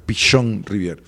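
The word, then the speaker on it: Pichon-Rivière: Psicología social que viene de, Pichón Rivier. Rivière. Rivier, Rivière, eh, porque se escribe Pichón, pero es Pichón Rivier.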